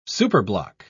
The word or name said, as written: superblock